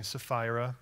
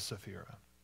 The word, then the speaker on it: Sapphira: But a man named Ananias with his wife Sapphira, you're probably saying Sapphira, but that's the Kentucky pronunciation, which is fine. I'll pronounce it Sapphira.